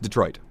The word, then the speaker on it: Detroit: It's pronounced Detroit. Okay. At least that's the way I always pronounced it, and I lived in Cleveland, the next major city over. – Okay. – So that's we always called it Detroit.